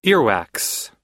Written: earwax

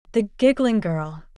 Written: The giggling girl

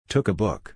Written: took a book

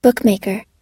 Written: bookmaker

